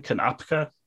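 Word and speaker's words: Konopka: Dan Kanroda from OK Go. Am I saying that right? – Dan Kanopka. – Kanopka. Okay.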